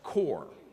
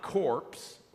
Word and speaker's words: Corps: Corps, C-O-R-P-S, is a French word. It comes from originally corpus, a Latin word, but when we use the word C-O-R-P-S, it's pronounced core. So it's not corpse or corp in that regard, it's core, and it refers to the body or a body.